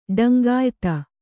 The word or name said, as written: ಡಂಗಾಯಿತ